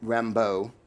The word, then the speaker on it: Rimbaud: Like Maeterlinck or D'Annunzio or Moreau or Rimbaud or Ram. I never say his name right. My wife will kill me. Rimbaud or Debussy, right?